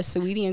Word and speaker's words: Saweetie: Yeah, Saweetie and Quavo.